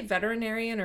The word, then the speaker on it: veterinarian: Because that's how it's spelled. Mm-hmm. It's veterinarian. But a lot of people say veterinarian, right? – Yeah, you're missing the E.